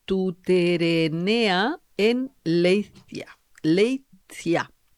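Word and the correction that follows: Leitza: Leiza. – Leiza. – Leiza. A ver si lo digo bien. Lo dije.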